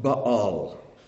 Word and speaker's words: Baal: This word ba'al is the word Baal. Baal. The pagan god, the Canaanite god Baal. It's pronounced the same way, even has the same vowel points. Ba'al is how it's pronounced.